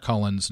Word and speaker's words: Cullins: It's Trish Schneider Cullins, not Cullen, like I initially call her when I bring her in, so get that out of the way.